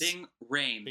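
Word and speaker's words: Rhames: how do you say his name? Haynes, Ving Rains, Ving Rains? – Ving Rains.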